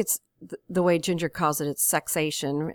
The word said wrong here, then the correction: sexcation: Am I right? Am I saying it right? – Sexcation. – Sexcation.